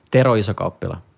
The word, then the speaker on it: Tero Isokauppila: Tero Isokauppila.